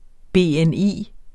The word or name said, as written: BNI